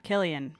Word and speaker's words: Cillian: Is it Cillian? Cillian Murphy? – Killian Murphy.